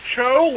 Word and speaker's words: Cho: It's pronounced Cho, like C-H-O, but okay. – A lot of people say So or T-So, and I'll answer to almost anything.